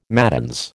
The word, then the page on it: matins: American English